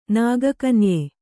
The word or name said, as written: ನಾಗಕನ್ಯೆ